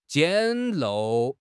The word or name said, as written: 简陋